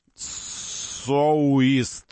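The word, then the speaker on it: Soueast: Вот, произносить нужно соуист, соу, как соуист, вот так.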